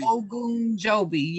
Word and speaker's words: Ogunjobi: I can't pronounce his last name. I think it's – Larry Ogunjobi. – Ogunjobi, yep.